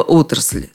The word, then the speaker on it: отрасль: Заметьте, вопрос об ударении относится исключительно ко множественному числу слова «отрасль». С единственным у него проблем нет. Отрасль, отрасли, отраслью, об отрасли. Везде ударение на начальном «о».